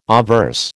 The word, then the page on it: obverse: American English